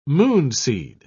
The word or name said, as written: moonseed